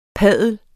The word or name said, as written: padle